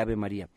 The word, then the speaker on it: Ave María: Ah, ¿no se dice así? No, no se dice así. Entonces, ¿cómo se dice? Se dice Ave María. Ave María, ¿ya lo dije bien? Sí.